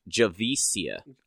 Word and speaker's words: Javicia: It was really because for those of you who listening who don't know, Ryan Wilder is played by Javika Leslie. Is that how you speak? – It's a soft C. Hang on, I have her Wikipedia page open somewhere. Pronunciation. It's Javicia.